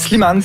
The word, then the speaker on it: Slimane: comme ça qu'on le prononce? – Slimane, tout à fait. – Slimane, mais c'est pas directement de lui que vous voulez nous parler.